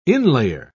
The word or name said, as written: inlayer